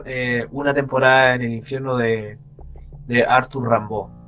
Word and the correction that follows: Rimbaud: Oye, no sé cómo se pronuncia en francés Rambaud, pero creo que creo que así, como Rimbaud. – el Rimbaud. – Mientras, no, mientras no le diga Rambo, estamos bien.